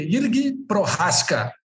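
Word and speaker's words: Jiří Procházka: Irg Prorasca, descobri como é que fala o nome desse cara. Fui atrás e descobri. É Irg Prorasca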